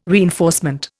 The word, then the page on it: reinforcement: Indian